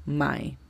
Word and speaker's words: my: La pronunciación fonética de esta es M-A-I, my.